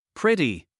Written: pretty

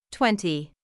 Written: twenty

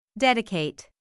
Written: dedicate

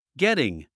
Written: getting

